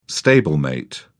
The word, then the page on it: stablemate: British English